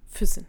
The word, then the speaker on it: Füssen: I want to talk again about Lake Constance, though, because on Lake Constance, there's a place called Füssen. Am I saying it right? – It's not directly on Lake Constance, okay. And you're almost right. It's called Füssen.